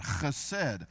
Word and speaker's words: chesed: That's how you say it, chesed.